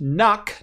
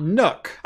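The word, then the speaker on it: NUC: Also, I love you and your work, but it's pronounced Nuck, not Nook. I'm willing to die on this hill. I was on the Intel campus in 2010 when they were talking about this, and even then they were split on the pronunciation.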